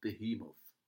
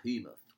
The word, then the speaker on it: Behemoth: And then in the film Reservoir Dogs, the radio DJ played by the comic Stephen Wright, he definitely pronounces it as Behemoth. – Behemoth. – Behemoth, with the emphasis on the he.